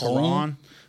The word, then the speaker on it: macaron: It's macaron. That's how you say it. – What? – Macaron. – Okay. – That's how you say it in French.